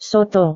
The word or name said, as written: soto